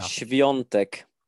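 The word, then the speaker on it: Świątek: Hur uttalar man hennes namn egentligen? Kan du dra okay. den här? Du En gång för alltid. Ja. Sviontek. Ja, bra, bra.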